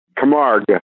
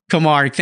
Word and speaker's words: Camargue: Camargue. – There we go. Camargue.